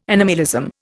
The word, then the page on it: animalism: Indian